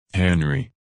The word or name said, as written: Henry